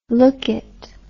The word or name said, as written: lookit